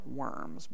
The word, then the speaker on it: Worms: city of Worms, spelled like worms, but pronounced Worms.